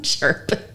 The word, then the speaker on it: cherubim: Did you see the cherubim? Well, how do you say it? – Cherubim. Putties.